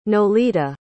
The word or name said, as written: nolita